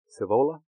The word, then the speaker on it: Sihvola: S-I-H-V-O-L-A, Savola.